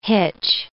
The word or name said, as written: hitch